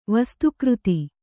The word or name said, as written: ವಸ್ತುಕೃತಿ